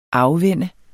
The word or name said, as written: afvende